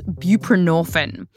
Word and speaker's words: buprenorphine: It's called buprenorphine.